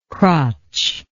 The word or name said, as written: crotch